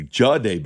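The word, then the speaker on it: Jahdae: Well, I say Jadae Barron. It's actually Jadae Barron.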